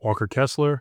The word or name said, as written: Walker Kessler